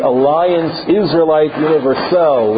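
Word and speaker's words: Alliance Israélite Universelle: So every shekel had help, gemacht, but nothing on the scale of the very first one, the Alliance Israelite Universelle, which you have to pronounce in French, because it was a French-Jewish organization.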